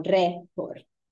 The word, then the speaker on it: récord: Rompiendo el récord, and I just wanted you guys to know that, sorry, they should have an, um, an accent over the E as well. Rompiendo el récord, your emphasis is on récord.